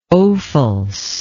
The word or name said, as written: Ophuls